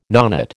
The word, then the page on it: nonet: American English